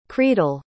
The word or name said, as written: creedal